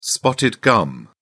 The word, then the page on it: spotted gum: British English